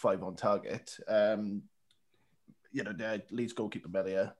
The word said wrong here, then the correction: Meslier: is, is how you say his name. – Yeah, Meslier. – Meslier, Melia.